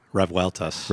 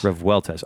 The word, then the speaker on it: Revueltas: Revueltas. – Revueltas. R-E-V-U-E-L-T-A-S. – R-E-V-U-E-L-T-A-S. – Did I say it wrong? – Yeah, no, you said it wrong. I think you spelled it wrong.